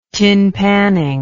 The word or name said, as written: tinpanning